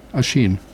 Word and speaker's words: Oisin: Because I didn't know how to pronounce Oisin. Which is O I S with I N with an accent over it. So it's Oisin.